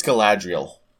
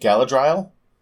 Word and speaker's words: Galadriel: Apparently it's Galadriel of Lothlorien. – Sure. – Yeah, I've always pronounced it as a kid, like, because I read the books before I did anything else. And so I've pronounced it Galadriel, which... – Yeah, that's wrong.